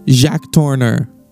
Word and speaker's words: Jacques Tourneur: Jacques Tourneur, who directed Out of the Past.